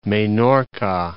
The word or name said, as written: Menorca